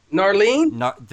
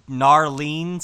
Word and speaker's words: Gnarlene: I don't know how to say this. G-N-A-R-L-E-N-E. – Narlene? – The Narlene song? – Yeah, the Narlene song.